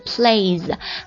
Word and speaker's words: plays: 还有就是玩耍这一单词 play 变成 plays，发音读 z 音呢？